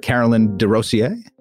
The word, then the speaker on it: Caroline Desrosiers: We're back with Scribely founder Carolyn Desrosiers. Did I do that right again? I did, right? – You did, actually. And it was a very nice pronunciation this time.